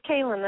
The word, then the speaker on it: Kalen: It's Kalen.